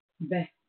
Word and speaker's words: Bechukotai: Our Torah portion for this week is Bechukotai. I got these words, I'm sorry, sometimes it's Bechukotai. There we go. I need to put the emphasis on the right syllable there.